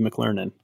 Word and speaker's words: McLernon: Well, I like to say that if McDonald's had a school and I was a student, I'd be McLernan.